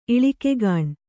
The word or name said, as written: ಇಳಿಕೆಗಾಣ್